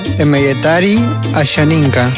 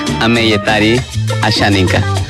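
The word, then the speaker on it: Asháninka: A ver, emeyetari ashaninka. – Ajá. Bueno, la pronunciación es Ameyetari Ashaninka.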